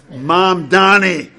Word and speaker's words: Mamdani: I know how to pronounce this. Mamdani, right, mayor of New York City.